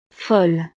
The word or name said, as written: folle